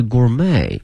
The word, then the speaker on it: gourmet: 同时啊，同学们到国外旅游的话呢，一些酒店提到了我们酒店能够提供的美食呢，它经常也用这个词，因此我们把这个词给大家推出来，叫 gourmet 它的发音是 gourmet。同时同学们注意了，后面这个 t 是不发音的， gourmet flavor 哈，一个美食的味道 flavor 后面加个 a worldwide 啊，全球范围内的一个 gourmet。